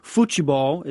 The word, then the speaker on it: futebol: And soccer, or futebol is how they say it there, is important from the time a kid can walk or barely walk.